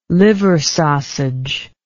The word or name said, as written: liver sausage